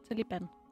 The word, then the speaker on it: Taliban: De kalder sig for de studerende af Koranen på pashto udtales det Taliban.